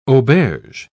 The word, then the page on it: auberge: British English